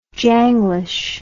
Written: janglish

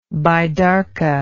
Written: baidarka

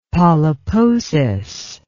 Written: polyposis

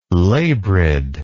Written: labrid

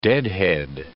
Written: deadhead

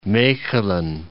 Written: Mechelen

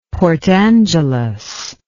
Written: port angeles

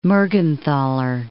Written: Mergenthaler

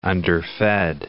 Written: underfed

